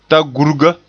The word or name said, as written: tagurga